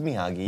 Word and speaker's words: Miyagi: Why are you doing soft G? It's Miyagi.